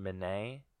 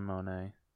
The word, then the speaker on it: Minnette: Vic Corelli, Bruno's older brother, I cast Dylan Minnette, or Minnette, however you pronounce that. – I think it's Dylan Monet.